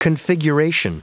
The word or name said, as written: configuration